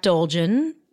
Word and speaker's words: Dolgen: I wrote it with Jack Dolgen, my longtime songwriting partner and rhymes with bulging.